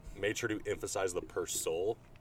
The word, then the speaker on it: Persol: So I look up Google, and Google's like, Persol. It's Persol. And so I was saying Persol or Sol, but it's like Persol.